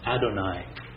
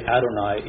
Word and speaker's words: Adonai: But because they won't even say his name, it's pronounced Adonai. That's how I said it when I pronounced it. And if someone is reading it in the Hebrew, they will say Adonai even though it says Yahweh.